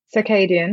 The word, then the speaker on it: circadian: Circadian.